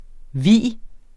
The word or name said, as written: vis